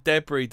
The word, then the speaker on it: debris: Your pronunciation of debris doesn't fit into either the US or UK versions. Drop the S on the end. We put the stress on the first syllable, the Yanks on the second.